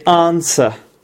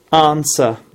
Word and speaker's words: answer: We do not say answer. We say answer. Answer. That's British English pronunciation. But there is never W sound. There is never W sound. Okay? Because it's silent in this word. We say answer. Just like we say who or whole. Okay? Or what else? Rack. All right, we do not pronounce W in a lot of words, actually, and this is one of them. Okay, so it must be answer.